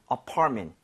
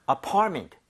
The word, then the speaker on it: apartment: So 강세를 제대로 주면 MENT가 그렇게 발음이 돼요. 여기 강세 주고 한번 해 보면 네. – apartment보다는 apartment. apartment.